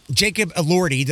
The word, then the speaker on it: Jacob Elordi: Jacob Elordi, did I say that right? – Yes, you did.